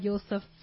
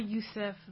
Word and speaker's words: Yousafzai: And they have stories like on Cleopatra, Amelia Earhart, Malala Yousafzai. – Mm-hmm. – Uh-huh. Am I pronouncing that right? – Malala Yousafzai. Yeah, something like that. I'm sorry. – Yousafzai, I believe.